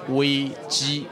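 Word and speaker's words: wei ji (危机): The Chinese word for crisis is pronounced as wei ji.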